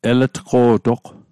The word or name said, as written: eletqo'toq